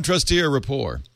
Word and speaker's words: Trusteer Rapport: wonders about, I don't know what this is, maybe you do, trusteer rapport. Am I saying that right? – Yes.